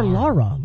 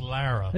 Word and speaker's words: Lara: Yeah, but he called her Laura. Like Laura. Like with the heavy... – Who cares? – The dad does. It's weird. I didn't think like that. But anyway... – It's technically pronounced Lara. – You're right. It's Lara.